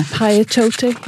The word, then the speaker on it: Piatote: For example, there's one from Beth Piatote. Is that how you pronounce her name? – That's it, Beth. – Yeah, Piatote.